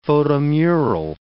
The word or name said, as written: photomural